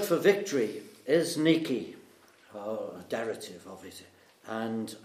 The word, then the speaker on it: Nike: Nike, as is pronounced in Greek.